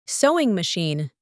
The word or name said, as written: sewing machine